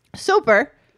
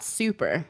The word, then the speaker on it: Soper: Soper... Again, hoping I pronounce this correctly. – It's not super. – No. – Because there's no U.